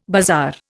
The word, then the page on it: bazaar: Indian